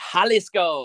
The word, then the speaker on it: Jalisco: I believe it's pronounced Jalisco.